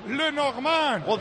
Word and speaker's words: Lenormand: Fíjate que bien lo pronuncia, Paco. Lenormand.